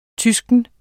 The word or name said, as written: tysk